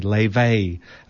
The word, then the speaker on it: Laufey: I've just discovered her through my son, actually, and I just heard this kind of jazz, kind of inspired voice, and her name is Leve. Well, that's how you pronounce it, Leve. It's spelled L-A-U-F-E-Y. It's actually an Icelandic name, and she's got Chinese and Icelandic heritage.